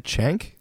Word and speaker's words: Cenk: Is it Cenk? – Yes.